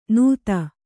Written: ನುತ್ತ

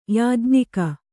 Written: ಯಾಜ್ಞಿಕ

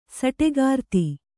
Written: ಸಟೆಗಾರ್ತಿ

